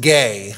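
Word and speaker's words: gay (γῆ): The word earth here in the Greek is pronounced gay. Um, that's just what the Greek word is.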